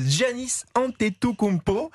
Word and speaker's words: Giannis Antetokounmpo: Janis euh, Giannis Antetokounmpo.